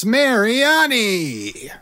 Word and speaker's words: Mariani: Thomas Mariani.